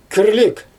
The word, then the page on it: kirlik: Xyzyl